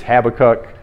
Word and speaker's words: Habakkuk: It's the book of Habakkuk. How many of you have read, studied the book of Habakkuk? Some of you may have referred to it as Habakkuk, different pronunciations.